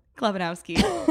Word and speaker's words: Chlebanowski: Chlebanowski.